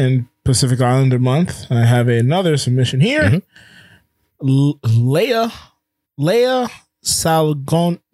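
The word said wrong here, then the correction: Lea Salonga: Names really trip me up. Leia, I was looking up the pronunciation before I got on here. Leia Salonga. That's, there we go.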